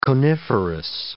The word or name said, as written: coniferous